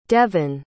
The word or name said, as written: Devan